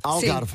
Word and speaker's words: Algarve: Começamos no Algarve. É, pronúncia é essa? – Algarve.